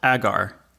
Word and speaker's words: Agar: I think it should be pronounced Agar. Agar Queen, because Agar is a scientific term. – I would have pronounced it Agar also, like Hagar the Horrible.